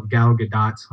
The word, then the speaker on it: Gadot: The cool thing is Gal Gadot. I believe that's how she properly pronounces it. – Yep, she corrected all of us. – Which it rhymes with hot, so that's like way cool.